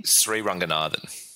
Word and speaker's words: Sriranganathan: Sriranganathan.